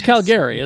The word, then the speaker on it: Calgary: It's Calgary, isn't it? – Yeah, that's right.